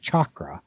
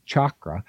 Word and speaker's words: Chakra: It's the 7-minute, Chakra. I've been mispronouncing it, I found out. It's Chakra Tune-Up, and it's free.